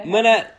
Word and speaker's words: Mineta: Mineta. Did I say that right? – Yeah.